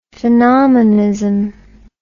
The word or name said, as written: phenomenism